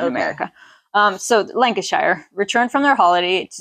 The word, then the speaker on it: Lancashire: A couple from Lancashire. – Lancashire. – I think I even said that right. You guys can correct me if I'm wrong, but I'm pretty sure it's Lancashire.